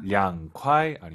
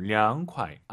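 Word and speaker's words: 两块: 선생님, 한국 분들은 초급자분들 특히 중급자도 마찬가지지만 이성경성이 어려워요. 량콰이 아니고 량콰이. 아하, 이 발음 기억하세요.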